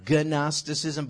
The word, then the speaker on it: Gnosticism: And the primary false teaching could be called Gnosticism, spelled G-N-O, Gnosticism, but you don't pronounce the G, from a word, Greek word, gnosis, meaning knowledge.